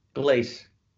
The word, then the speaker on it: Glace: But is you're from Glace Bay or Gloss Bay? How do I say that? – I was born in Glace Bay, Cape Breton. Glace. – Glace. Okay. Yeah.